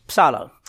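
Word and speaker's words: psallo: That's the word from Psalm, P-S-A-L, but it's P-S-A-L-L-O. And the O is a long O. It's got a little dash along the top of it. It's psalo.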